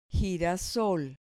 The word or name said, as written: girasol